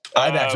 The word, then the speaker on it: IBEX: IBEX.